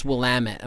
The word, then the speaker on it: Willamette: And he's like, no, it's Willamette Mountain. It's pronounced Willamette.